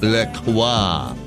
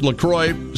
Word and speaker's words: La Croix: So the official, and I'm so sorry, the official pronunciation is not La Croix. Not so fancy schmancy, because it's from Wisconsin, after all. There's no cheese in it yet. It is pronounced La Croix, so it rhymes with enjoy.